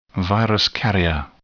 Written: virus carrier

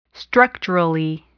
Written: structurally

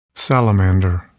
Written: salamander

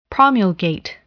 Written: promulgate

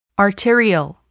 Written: arterial

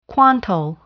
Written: quantile

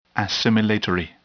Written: assimilatory

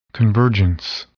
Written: convergence

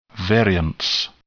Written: variance

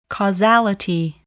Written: causality

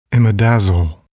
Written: imidazole

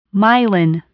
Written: myelin

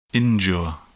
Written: injure

